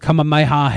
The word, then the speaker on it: Kamehameha: Kamehameha Harris. Running for president. Kamehameha. That's how it's pronounced.